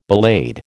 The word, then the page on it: ballade: American English